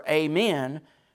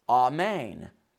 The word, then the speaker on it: amen: And it comes from the Hebrew word amen, which is very similar to how we say amen, right? Amen is how apparently you pronounce that.